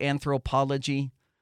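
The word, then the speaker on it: Anthropologie: Am I saying it right? Anthropology? – Yeah, you're saying it right.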